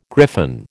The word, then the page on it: griffin: American English